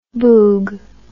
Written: boog

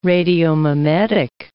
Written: radiomimetic

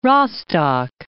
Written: Rostock

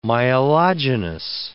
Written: myelogenous

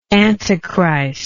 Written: antichristianly

